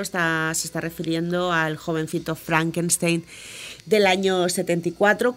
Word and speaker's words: Frankenstein: Gene Wilder interpretó a Frederick Frankenstein, pronunciado Frankenstein.